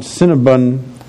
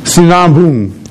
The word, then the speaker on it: Sinabung: Just this morning I read about Mount Cinnabon. Bung, I think that's the way to pronounce it. Sinabung.